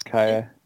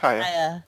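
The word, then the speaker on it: Kaia: I think it's Kaya. – It's Kaya.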